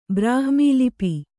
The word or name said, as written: ಬ್ರಹ್ಮಲಿಪಿ